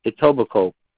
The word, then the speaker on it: Etobicoke: I don't know how to pronounce that, but it is E-T-O-E-I-C-E. I could never pronounce that. – B-I-C-O-K-E. E-T-O-B-I-C-O-K-E. Etobicoke.